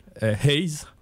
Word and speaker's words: HAZ: Notre groupe, Haze, H.A.Z. – Répète un peu. – Haze, mais ça s'écrit H.A.Z.